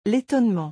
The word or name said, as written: L'etonnement